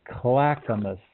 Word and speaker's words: Clackamas: I know how to say this word. Clackamas.